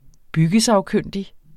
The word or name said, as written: byggesagkyndig